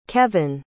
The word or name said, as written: kevyn